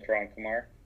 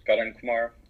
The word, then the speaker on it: Karan Kumar: You could just type in my name, Karan Kumar, Karan Kumar.